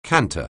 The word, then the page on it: canter: anglais